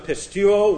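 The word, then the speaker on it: pistuo (πιστεύω): This is the little aorist active indicative of pistuo. We've been very familiar with this. P-I-S-T-E-U-O, pistuo. P-I-S-T-E-U-O, long O on the end.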